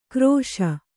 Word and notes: ಕ್ರೋಶ: krōśa; Kannada

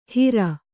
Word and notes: ಹಿರ: hira

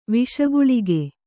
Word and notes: ವಿಷಗಳಿಗೆ: viṣa gaḷige

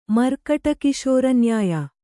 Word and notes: ಮರ್ಕಟಕಿಶೋರನ್ಯಾಯ: markaṭa kiśora nyāya

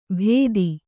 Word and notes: ಭೇದಿ: Kannada

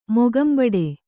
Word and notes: ಮೊಗಂಬಡೆ: kannada

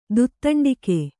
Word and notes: ದುತ್ತಂಡಿಕೆ: Kannada

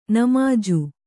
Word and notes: ನಿಮಾಜು: nimāju

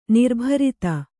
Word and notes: ನಿರ್ಭರತೆ: nirbharate

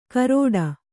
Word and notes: ಕರೋಡ: Kannada

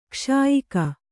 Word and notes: ಗಾಸಿಯಾಗು: kannada

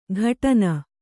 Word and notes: ಘಟನ: ghaṭana

